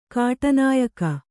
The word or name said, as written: ಕಾಟನಾಯಕ